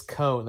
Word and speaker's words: Coan: Well, it's Cohen, like C-O-A-N, but I've heard a lot of like hard pronunciation of the O to the A.